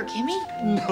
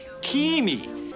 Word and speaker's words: Kimi: This is your sister, Kimi. You named her Kimmy? No, Kimi.